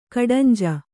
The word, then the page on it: ಕಡಂಜ: kaḍanja